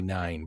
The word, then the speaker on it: Nein: The second campaign is the Mighty Nine, but it's spelled N-E-I-N. So the Mighty, like, no. – Oh, nine.